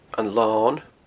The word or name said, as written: Lon